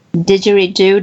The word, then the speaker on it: didgeridoo: Cannot live without 3 things: speaking, basketball, and playing his didgeridoo. Did I pronounce it correctly? – Yeah, you pronounce it correctly.